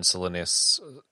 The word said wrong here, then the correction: Silenus: You know how they say it in the audiobook? – How? – Silenus.